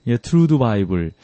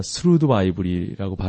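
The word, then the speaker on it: Thru the Bible: 자, 이렇게 해서 데살로니가 전서 후서를 마치고 다음 시간에는 구약으로 가서 트루드 바이블, 스루드 바이블이라고 발음을 해야죠. 이 성경,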